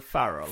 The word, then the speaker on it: Ferrell: Jag tror det uttalas Will Farrell.